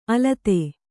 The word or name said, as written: ಅಲತೆ